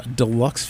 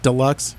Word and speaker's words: Deluxe: We have some Deluxe Fiasco here. – Deluxe. – Not deluxe. Deluxe. Depends. Emphasis on the first syllable.